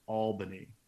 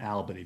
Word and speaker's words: Albany: As a New Yorker, I feel I have to say it's pronounced Albany, not Albany. People have been pronouncing Albany on TV, and I'm like, start it. It's Albany. Sorry.